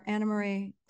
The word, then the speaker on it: Annemarie: And she actually had to explain because it's spelled Annemarie. – I know. I know. – So so whatever, Anna Marie.